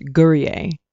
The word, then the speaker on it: Gourier: I've gotten Gourier, Gourier, Gourier, everything that it is not, and it's simply pronounced Gourier, just like you would pronounce any French last name, or with an I E R, you add the A. So the ER at the end isn't pronounced.